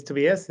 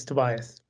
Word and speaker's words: Tobias: So my name is Tobias Panborg. It's Swedish pronunciation is Tobias. Usually when I talk English, it's Tobias.